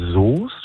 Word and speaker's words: Soest: Da wäre ich bei Soest. Schreibt sich ja mit OE, aber man spricht das O aus.